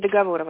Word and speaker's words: договоров: Девушка, меня зовут Анастасия, агентство договоров. Вчера вот с кем-то общалась, мне говорили скинуть коммерческое предложение. Вот, коммерческое предложение я скинула, вы получили письмо? – Да, правильно говорить договоров, Анастасия.